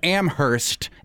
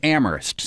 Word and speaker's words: Amherst: We just got an angry email from a listener the other day saying, how dare you not correct your guests when they call it Amherst instead of Amherst. – Yeah. – So yep. – No going forward that in Amherst, only the H is silent. – Yeah. And you're from Ohio where they do pronounce the H for the Amherst. – Mm-hmm. So Amherst, Mass, is no H.